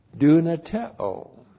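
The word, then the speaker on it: Dunateo (δυνατέω): And God the Father is the subject of the verb dunateo. D-U-N-A-T-E long O. Dunateo.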